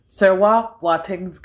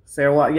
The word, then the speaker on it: Serwa Boateng: So it's pronounced Serwa Boateng's Guide to Vampire Hunting. Serwa, yeah, it's almost like the English name Sarah, but Wa kind of. Serwa.